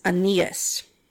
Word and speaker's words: Aeneas: So Peter went to the city of Lydda, where there was a man named, I looked this up, it's spelled weird, but it's Aeneas.